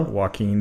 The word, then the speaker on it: Joaquin: Joaquin Phoenix.